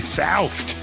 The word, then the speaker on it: South: yeah, you, gotta say it like that. It's not soft, it's the NFC South. South. Kind of sounds like yeah, right.